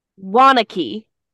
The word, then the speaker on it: Waunakee: Waunakee.